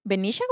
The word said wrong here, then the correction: Venetia: ¿Como Venisha del Toro, pero Venetia? – Eh, no, se escribe como Venecia, pero con T. – Ah, Venetia. Ya, Venetia.